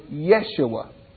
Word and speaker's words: Yeshua: There is no J in Hebrew. It's a Y. So the way that Jesus would have heard his name pronounced probably, and the way that Joshua would have heard his name pronounced is Yeshua.